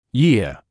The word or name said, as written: year